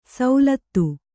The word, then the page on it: ಶ್ಲಥ: ślatha